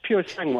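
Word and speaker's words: Purosangue: Did we get the name right, first of all? – Yeah, I think so. That was a pretty good attempt. Puro Sangue.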